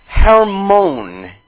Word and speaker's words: Hermon: Into Harmon. Into Harmon. Hermon is how you would pronounce it in Hebrew, and Strong says a castle.